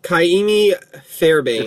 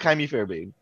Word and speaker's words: Kaimi Fairbairn: Kaimi Fairbairn. – It's Kaimi. It's just Kaimi Fairbairn.